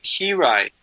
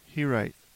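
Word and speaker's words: Hiraeth: Yeah, it's you pronounce it Hirite. – Hirite, okay. – Hirite, and it's a Welsh word.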